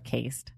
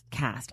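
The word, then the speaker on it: Caste: Is that how you say it? Cast or cased? – Cast. – I always fuck this up. The E, any other word, the E makes the vowel say its name.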